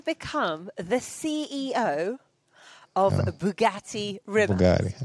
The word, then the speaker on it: Rimac: He pronounced it in Croatian Rimac. It's spelt Rimac.